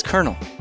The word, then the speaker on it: colonel: That one was easy for me because that's just how it's pronounced. It's Colonel. – Colonel? – Yeah, yeah. Like the popcorn.